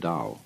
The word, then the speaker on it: Tao: that we can make to a Chinese word, Tao. You know, that's spelled T-A-O. I don't know why, because it's usually pronounced Dao.